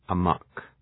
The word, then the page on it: amok: ə’mɒk